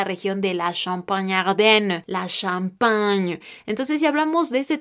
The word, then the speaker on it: champagne: Algunos ejemplos con este sonido que lo haremos con la CH juntas será le champagne, le champán, que se escribe el E por el artículo y enseguida C-H-A-M-P-A-G-N-E champagne.